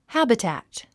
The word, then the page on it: habitat: hǽbitæ`t; ハァビタッツ